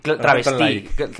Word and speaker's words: travesti: Travesti. – Travesti, con acento en la I, justo.